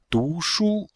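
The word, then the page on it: dushu: du2shu1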